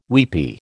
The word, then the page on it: weepy: American English